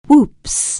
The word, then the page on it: whoops: anglais